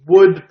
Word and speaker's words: Wood: And then, okay, this is Flow.Green's Wood Project. Oh, I'm sorry. They want it pronounced Wood, Woo-D. They want you to hit that D like it's the letter D.